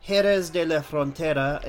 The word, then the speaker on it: Jerez de la Frontera: Sherry is a fortified wine made from white grapes that are grown near the city of, well, it would be pronounced Jerez de la Frontera in Andalusia, Spain.